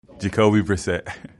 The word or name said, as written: Jacoby Brissett